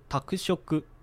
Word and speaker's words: 宅食: こちら宅食プロジェクトなんですけれども、この宅食という部分漢字なんですけれども、自宅の宅に食べると書いて宅食と読みます。